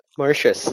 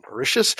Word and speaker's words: Mauritius: While some chapters were written on the benches of, how do I pronounce that? – Mauritius. – On the Mauritius.